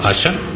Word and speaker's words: Asa: No, o sea, esta cantante se llama Asha. Así se pronuncia, se escribe Asa.